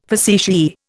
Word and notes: facetiae: Indian

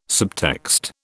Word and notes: subtext: British English

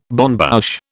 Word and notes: bonne bouche: British English

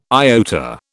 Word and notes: iota: British English

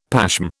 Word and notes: pashm: British English

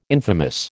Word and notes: infamous: American English

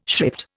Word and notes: shrift: Indian

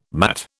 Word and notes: matt: British English

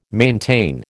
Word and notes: maintain: American English

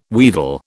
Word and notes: wheedle: British English

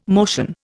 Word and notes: motion: Indian

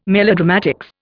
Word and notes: melodramatics: Indian

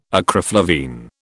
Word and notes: acriflavine: British English

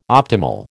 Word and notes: optimal: American English

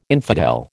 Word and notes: infidel: American English

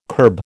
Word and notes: kerb: American English

